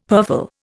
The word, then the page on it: purfle: Indian